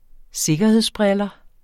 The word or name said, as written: sikkerhedsbriller